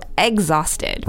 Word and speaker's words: exhausted: So exhausted, if you look at the spelling, it says E-X-H, but this is not how it's pronounced. It's more similar to egg, so exhausted.